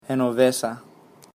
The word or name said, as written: Genovesa